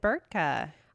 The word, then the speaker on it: Burtka: And David Burtka.